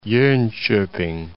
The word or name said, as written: Jonkoping